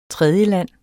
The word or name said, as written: tredjeland